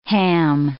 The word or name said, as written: ham